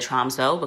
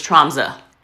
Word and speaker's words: Tromsø: From Oslo, which I did not get to see, I went to northern Norway, a city called Tromsø, which is actually the correct way to pronounce it. I initially said Tromsø because that's the way it's spelled, but it's that O with the line through it. So it's Tromsø.